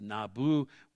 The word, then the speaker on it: Nabu: Nebo in Akkadian is pronounced Nabu, was supposedly Marduk's son, one of the chief deities in their pantheon.